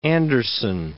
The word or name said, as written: Andersen